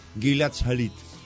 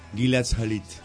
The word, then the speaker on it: Gilad Shalit: Después de 5 años de cautiverio, la organización Hamas liberó al soldado israelí, fíjense la pronunciación, Gilad Shalit. – ¿Y? – Muy bien. – Gilad Shalit.